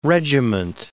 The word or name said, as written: regiment